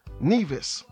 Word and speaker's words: Nevis: The first one is the island of Nevis, N-E-V-I-S, right?